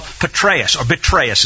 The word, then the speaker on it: Petraeus: And he says, among others, I read that a four-star general, Petraeus is the way that should be pronounced,